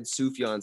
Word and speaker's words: Sufjan: I've always heard it said Sufjan Stevens, but Subaru is a little nickname that they have for him.